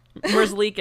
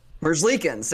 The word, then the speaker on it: Merzlikins: Merlinski? – Merzlikins. – Yeah. – Merzlikins, there we go.